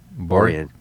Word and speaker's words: Boriyuan: And it's a, how do you pronounce that? Borian? – Borian. B-O-R-I-Y-U-A-N. Wow.